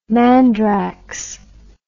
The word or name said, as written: mandrax